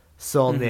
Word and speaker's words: Saulnier: His name's Jeremy Saulnier. That's it's a French. I looked up the pronunciation because I didn't want to fuck it up. – Saulnier.